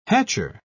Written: hatcher

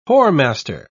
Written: whoremaster

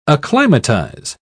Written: acclimatize

